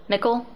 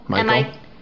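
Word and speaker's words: Mikal: Mickle? – Michael. – Oh, Michael. It's M-I-K-A-L.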